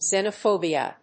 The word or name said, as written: xenophobia